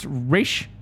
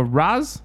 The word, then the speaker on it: Ra's al Ghul: Sir, is it pronounced Raish or Raz? – Depends who you talk to.